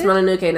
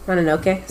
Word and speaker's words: Mononoke: About Princess Mononoke now. Sorry. – It's not. Here, let me show you. – Mononoke? Sorry, I mispronounced.